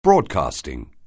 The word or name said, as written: broadcasting